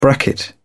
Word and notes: bracket: British English

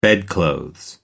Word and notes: bedclothes: American English

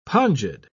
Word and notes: pongid: British English

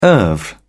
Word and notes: oeuvre: British English

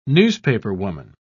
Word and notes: newspaperwoman: British English

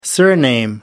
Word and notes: surname: American English

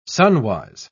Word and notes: sunwise: British English

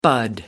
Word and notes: bud: American English

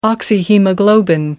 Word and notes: oxyhaemoglobin: American English

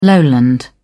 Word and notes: lowland: British English

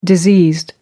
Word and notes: diseased: American English

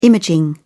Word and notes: imaging: British English

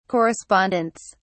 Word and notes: correspondents: British English

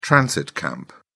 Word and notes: transit camp: British English

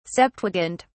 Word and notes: septuagint: British English

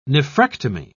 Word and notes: nephrectomy: British English

